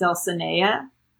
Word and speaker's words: Dulcinea: So, like, the audiobook pronounces it Dulcinea. So that's what I'm going to go with.